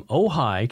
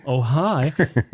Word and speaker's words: Ojai: Next up, we have Lisa Marshall, and Lisa is from Ojai, California. – Ojai. – Ojai. It's spelled O-J-I-A, or O-J-A-I. So, Ojai.